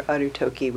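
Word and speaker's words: Arutoki (ある時): It can be read Uji, which is derived from the Chinese pronunciation, or Arutoki, which would be the Japanese.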